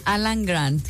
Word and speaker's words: Alan Grant: Alan Grant. – Se escuchó hasta la T marcada y todo.